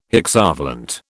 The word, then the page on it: hexavalent: British English